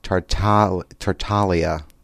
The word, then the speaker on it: Tartaglia: It's Tartaglia. The G is silent, he said.